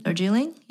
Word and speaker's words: Darjeeling: So then we go into number 7 is Darjeeling. – Oh, did I say that right? – Darjeeling.